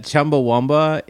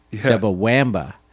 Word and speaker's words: Chumbawamba: My first all I remember is that Chumbawamba. It's spelled W A M B A. Chumbawamba. – I know that. Yeah, Chumbawamba. – Chumbawamba. – I guess if you say it in an Australian accent, it's correct.